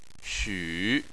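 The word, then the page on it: xu: tone 3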